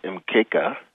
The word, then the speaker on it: mkeka: and that's spelled M-A-Z-A-O. It's placed on a mat called the mkeka, and that is M-K-E-K-A.